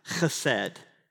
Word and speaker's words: chesed: It's a Hebrew word that, you can't make fun of me because it's hard to say, it's a Hebrew word that's somewhat pronounced chesed. So there's a ch or a kh anytime in Hebrew when it's transliterated, it translates KH. That's the clear your throat sound that I can't do very well because I'm not Jewish, but I might look it, but I'm not.